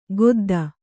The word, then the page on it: ಗೊದ್ದ: godda